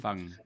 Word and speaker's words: Fung: Was it Dr. Fong? Dr. Fong? I always say it wrong. – Fung. The fasting Fung.